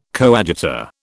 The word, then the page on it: coadjutor: British English